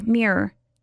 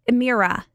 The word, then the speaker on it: mirror: Mira is how I used to say it. Mira. – Oh, I say it like a year, but put an M. Mirror. – Mirror. – I totally like skip out on the R. It's just say mirror.